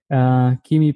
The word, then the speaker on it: Kimi: Kimi, Pua, welcome to the Gratitude Podcast.